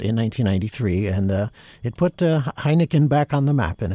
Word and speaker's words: Heineken: No one remembered Johann David Heineken, and you wouldn't even say it without giggling a little bit, because it's, even though it's spelled differently, it's pronounced the same way as the beer.